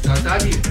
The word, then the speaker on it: Tasnadi: Ja, Tasnadi.